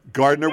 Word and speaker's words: Gardiner: Gardiner with a hard G. We're pronouncing it correctly.